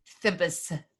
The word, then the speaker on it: Thebes: I realize I didn't say that clearly. Thebes.